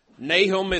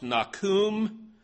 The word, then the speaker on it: Nahum: Nahum is a prophet with a great name. Literally, his name could be pronounced Nakum, which is closely related to the Hebrew word for comfort, Nakam.